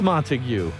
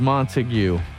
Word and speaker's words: Montague: It's either in Montauk or Montague. Not sure how to pronounce that place. It is Montague. Joe of Skullduggery fame confirms Montague.